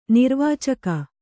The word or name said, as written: ನಿರ್ವಾಚಕ